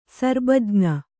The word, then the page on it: ಸರ್ಬಜ್ಞ: Kannada